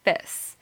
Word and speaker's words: bis: Okay, so like bliss without the L. Okay, so bis.